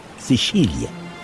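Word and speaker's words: Sicilia: عند جزيرة صقلية وتنطق باللغة الإيطالية سيشيليا.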